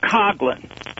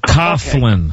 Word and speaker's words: Coughlin: One is Tom Coughlin. – Coughlin. – Coughlin.